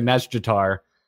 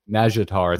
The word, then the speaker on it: Nazjatar: So it's kind of driving me nutty because I'm hearing all the crazy Naga ladies say it, and they do pronounce the J, but extremely quickly and more quickly than I can make my mouth work. – Yeah. – So they do say like a Nazjatar, but I can't do that. Yeah. So I just say Nazjatar. It's like a three syllable word, and I'm done.